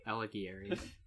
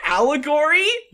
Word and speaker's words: Alighieri: Alighieri. – Alighieri. Is his last name Allegory? – No. – Just mispronounced.